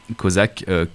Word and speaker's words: Kozak: Kozak, mon nom d'artiste, forcément. – Kozak, qui s'écrit comment? – Kozak, K-O-Z-A-K.